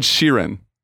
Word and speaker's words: Sheeran: And, and now you think it's Ed Sharon? Is that how you pronounce it? I thought his name was Ed Sheeran. Did I say Sharon? You said like Sharan or something. It's it's Ed Sheeran.